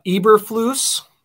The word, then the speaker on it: Eberflus: Um, with the Broncos, the Packers OC, uh, Eberflus Am I pronouncing that correct? I believe. Yes, correct.